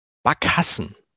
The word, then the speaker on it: Barkassen: Backhassen mit Betonung auf dem zweiten A.